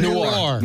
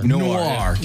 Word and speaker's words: Noir: I can't ever say Noir. – Noir.